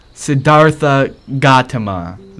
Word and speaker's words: Siddhartha Gautama: So now instead of calling him SG, short for Sadama, oh my god, see this is why I don't pronounce his name every time now. The first try has been the same. Siddhartha Gautama.